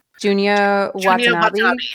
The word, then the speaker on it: Junya Watanabe: Junya Watanabe.